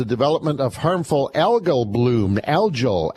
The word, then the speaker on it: algal: So anyways, we call it algae blooms, but it's algal blooms.